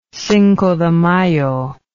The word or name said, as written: cinco de mayo